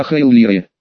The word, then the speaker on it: Эллира: Да и Эллира вовсе не так нужно произносить. А Хаэллире, это если дословно звуки привести. Просто Ха произносится очень мягко, невнятно.